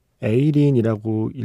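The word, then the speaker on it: A-Lin: 에이린이라고 읽어야 되겠죠? A 그리고 L-I-N이니까요.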